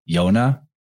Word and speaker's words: Jonah: Another character who's lost on the waters was Jonah. And Jonah is a weird one. First of all, it sounds like if you give it the soft J, it sounds like Yona.